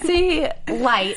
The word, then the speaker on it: T-Lights: And she's really nervous because she sees T-Lines and... – T-Lights. – Why do I keep saying that? T-Lights. – It's like Snoop Lion. – Oh, yeah. I gotta say it like T-Lights.